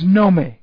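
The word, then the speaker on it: nome (γνώμη): Now, the Greek word is nome. G-N-O, long O-M-E, means to discern, means to know.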